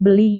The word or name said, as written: beli